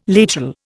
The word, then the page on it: lateral: Indian